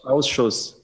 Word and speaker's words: Ausschuss: How do you pronounce it? Corona Ausschuss? – Ausschuss, yeah. So Ausschuss.